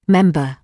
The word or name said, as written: member